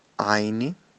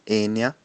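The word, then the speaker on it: Áine: Aí a gente vai ter de forma muito, muito clara, por exemplo, a deusa Aine, uma pronúncia mais adequada para o nome seria Enya, né, mas a escrita para a gente em português seria lido como Aine, então muita gente acaba entendendo o nome dessa forma, né?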